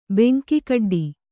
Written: ಬೆಂಕಿಕಡ್ಡಿ

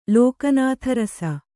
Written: ಲೋಕನಾಥರಸ

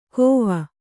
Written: ಕೋವ

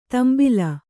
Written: ತಾಂಬೇಲು

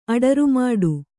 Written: ಅಡರುಮಾಡು

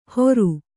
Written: ಹೋರು

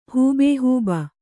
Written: ಹೂಬೇಹೂಬ

